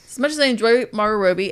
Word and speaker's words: Margot Robbie: And the thing is, what was really frustrating was that as much as I enjoy Margot Robbie, I never say her name right. – Margot Robbie.